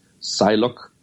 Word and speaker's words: Psylocke: Maar eerder omdat zijn zus, Psylocke. – Ja, de P is silent. – Ja. – See what I did there. – Psylocke.